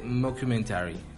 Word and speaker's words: mockumentary: Por eso en el 2006, cuando se concreta la película de Borat, se toma el formato de mockumentary. Lo dije bien, tú lo dices mucho mejor. Mm, mockumentary.